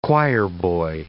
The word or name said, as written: choirboy